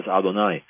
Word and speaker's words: Adonai: But in the complete Jewish Bible, when we reread the Torah, when I read the Torah, we automatically pronounce those letters not as Yah, and I won't say the rest of it, but as Adonai, which means Lord.